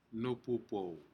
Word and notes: nupupou: Aneityum

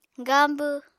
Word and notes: gaambw: Apatani

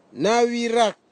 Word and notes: nawirak: Nafe; Tanna

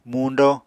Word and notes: muñdo: Apatani

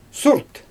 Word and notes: surt: Xyzyl

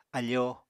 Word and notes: afoo: Apatani

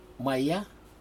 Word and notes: maya: Aneityum

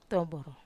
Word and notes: tomboro: Wamesa

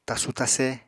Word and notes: tasu-tase: apatani

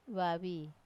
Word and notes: vavi: Wamesa

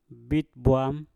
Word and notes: bit bwam: mpur

